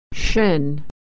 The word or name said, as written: shin